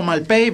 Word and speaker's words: Malpaís: Yo siempre lo he escuchado Malpay, pero sí, es verdad que se escribe que es Malpaís, pero bueno, siempre lo pronunciamos un poquito rápido.